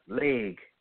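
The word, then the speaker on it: legh: The word law comes from the root L-E-G, leg. And then the other root is another, it's pronounced leg, but it's L-E-G-H. L-E-G, the first root, means gather, set in order, consider.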